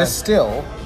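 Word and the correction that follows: Destihl: All right, it's Destil.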